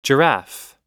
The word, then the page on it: giraffe: noun